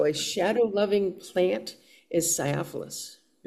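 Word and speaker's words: sciophilous: S-C-I-O, the C is apparently soft. So it's not Sciophilus, it's Sciophilus, thanks to the Oxford English Dictionary for that pronunciation guide.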